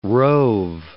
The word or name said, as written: rove